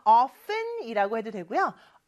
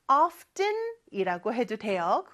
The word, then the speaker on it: often: 어, 근데 왜 T를 발음 안 할까요? 해도 되고 안 해도 됩니다. 그래서 often이라고 해도 되고요. often이라고 해도 돼요. 그거는 여러분들이 편한 대로 해주시면 돼요.